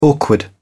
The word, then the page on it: awkward: British English